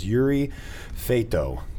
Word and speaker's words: Yuri Feito: I'm your host, with Nick Rhodes, Brandon Phillips, and our guest today is Yuri Feito.